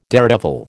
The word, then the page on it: daredevil: American English